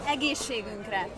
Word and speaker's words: Egészségünkre: Egészségünkre.